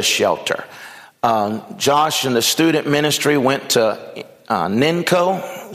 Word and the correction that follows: NECO: Is that how you say it? NECO. There you go. NECO.